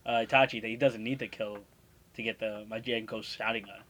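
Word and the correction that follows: Mangekyō Sharingan: Eventually, I'll learn how to say that phrase correctly. Mangekyo Sharingan.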